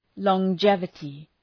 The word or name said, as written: longevity